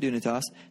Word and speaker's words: Dunetos: That's spelled D-U-N-E-T-O-S, Dunitas. The first question I get is, how do you pronounce it? You pronounce it Dunitas.